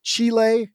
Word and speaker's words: Chile: You know, Nigeria, Kenya, Chile. And that's the right way to say that.